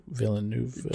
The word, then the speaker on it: Villeneuve: But the director Dennis Villeneuve, Villeneuve,